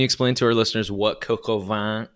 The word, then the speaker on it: coq au vin: And so one of the reasons I went all the way out to Jersey is because my friends were making coq au vin. Is that how you say it? Coq au vin? – Yeah, it's coq au vin.